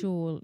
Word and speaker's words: Scholl: like you'd be as well stick with the Schol ones. Is that what you call them? – Schol, yeah, you pronounced it a lot better than me.